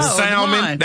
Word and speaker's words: salmon: Salmon. That's salmon. – Salmon has that, well, that L is supposed to be silent, ain't it? – Kind of, sort of.